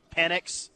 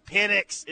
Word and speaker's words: Penix: By the way, can someone tell Desmond Howard that that's the correct way to pronounce it? It's Penix.